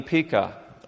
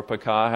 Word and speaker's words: Pekah: Okay, the next king in this is Lepudrier here, is going to play King Pekah, or Pekah, however you want to pronounce his name.